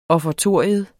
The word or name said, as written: offertorium